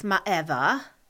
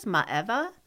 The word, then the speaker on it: Maeva: Well, the Polynesian version of the name is pronounced Maeva, and I believe that's the name that is becoming popular in kind of the French cultures, Maeva.